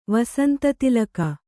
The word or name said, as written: ವಸಂತತಿಲಕ